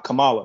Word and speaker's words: Kamala: It's funny, I was having a conversation earlier this weekend, and it's like, it's kind of offensive, like, to not pronounce it like Kamala. It's Kamala.